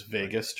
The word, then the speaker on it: vagus: It is vagus, just like Las Vegas, but spelt V-A-G-U-S.